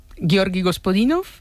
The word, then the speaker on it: Georgi Gospodinov: A ver, Georgi Gospodinov, sí. – Zdravka Eftimova y Vera Mutavcheva. – Ahora sí, ahora sí.